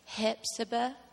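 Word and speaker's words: Hephzibah: You have to say it with a southern accent, otherwise you don't pronounce it correctly. It's Hepzibah, Georgia.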